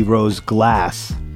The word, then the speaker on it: Rose Glass: Well, first off, talking about pronunciation of names, you said Rose Glash. I didn't know if that was because you were about to read British next or if that was... actually might be Rose Glass.